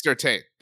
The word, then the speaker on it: Surtain: I mean, we said Surtain. I'm probably mispronouncing it, whatever. – You're certain it's Surtain. There we go. – There. Yeah, perfect.